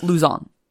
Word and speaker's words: Luzon: I don't know why I gave that a French accent. – Is that not how it's pronounced? – No. It's like Luzon.